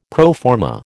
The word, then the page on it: pro forma: American English